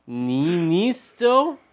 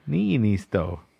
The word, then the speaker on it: Niinistö: So, yeah, the Finnish president, I'm going to butcher his name, Sauli Niinistö? – Didn't you learn anything? Sauli. – Sauli. – Come on, dude. – Niinistö. – There's two I's in there. Niinistö.